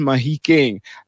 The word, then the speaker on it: Mahikeng: Mafeking area, or also commonly recited, or rather pronounced as Mahikeng.